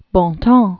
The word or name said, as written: Arna Wendell Bontemps